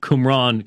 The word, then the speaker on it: Qumran: We've played the interview, um, and it, it came up in the interview the discussion of the Qumran community.